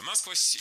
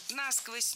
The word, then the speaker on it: насквозь: Если бы лед падал на дно, то у нас бы промерзали бы все водоемы, собственно, зимой насквозь, и весной бы размораживалось уже все стерильное. – Насквозь, неверно. Ударение в этом наречии должно падать на второй слог. Насквозь. Так зафиксировано в словарях, только насквозь.